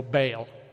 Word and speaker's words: Baal: That's what we call Baal.